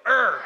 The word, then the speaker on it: Ur: You got to say it like you're angry, though. Ur.